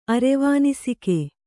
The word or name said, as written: ಅರೆವಾನಿಸಿಕೆ